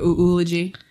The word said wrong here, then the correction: eulogy: And I will. – You googly. – Sorry, I even mispronouncing it wrong. Okay. But, I'll be writing a eulogy,